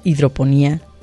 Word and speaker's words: hidroponía: Y en este caso, sin acento hidroponía, hidroponía. – Con acento en la I.